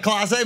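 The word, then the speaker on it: Clase Azul: Even though he pronounced it wrong, he's back. Yeah. He said Case, not Classe, but it was so good.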